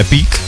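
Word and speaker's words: Epiq: Pak je Epic, to je Epic.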